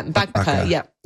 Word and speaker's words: bakbaka: There's another recipe, and boy, I'm not confident that I will pronounce this correctly, but it's a one pan bakbaka. – Yeah, bakbaka. Yeah, that's it. You did well. That's it. Bakbaka.